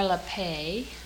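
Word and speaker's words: Lappé: It's by Frances Moore LaPappe, L-A-P-P-E, with an accent on the E.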